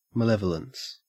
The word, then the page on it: malevolence: Australian English